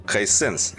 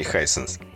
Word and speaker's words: Hisense: Hisense, Hisense, а не Hisense.